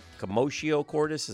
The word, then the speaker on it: commotio cordis: As you watched it, you texted me immediately, commotio cordis, is that how you say it? – Correct.